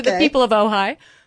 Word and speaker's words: Ojai: They are in, I'm not sure if I'm pronouncing it correctly, but Ojai, California. – Ojai. I happen Ojai. – To know that.